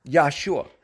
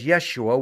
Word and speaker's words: Yahshua: That's how you pronounce it, Yahshua, which is the same name that they mistransliterate or misphonetically pronounce in the modern Hebrew as Yeshua, which is a girl's name, and as Jesus in the English, and as a Greek name.